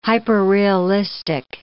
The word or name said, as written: hyperrealistic